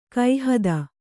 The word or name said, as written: ಗೋಹತಿ